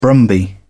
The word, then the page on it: brumby: British English